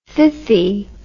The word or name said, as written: thithy